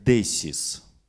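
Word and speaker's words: десис (δέησις): Слово молитва а, по-гречески произносится как «десис», то есть описывает горячую просьбу к Богу.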